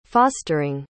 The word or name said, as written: fostering